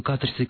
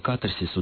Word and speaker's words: катарсис: утверждая, что слово катарсис, ударение должно ставиться только на первом слоге, а в большинстве европейских языков и в древнегреческом ударение ставилось на второй.